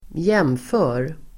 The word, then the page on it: jfr: [²j'em:fö:r]